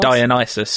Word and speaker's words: Dionysus: Dionysus, the god of wine.